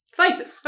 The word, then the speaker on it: phthisis: You were right. It's just thysis. I preferred fucking it up as hard as possible, but like... – It helps to know what we're talking about. – It does help to know what we're talking about. So it's thysis. If you hear me saying thysis, it's because it's spelled like that.